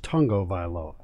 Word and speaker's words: Tagovailoa: So I learned today, even though it's spelled Tagovailoa, it's pronounced Tungovailoa.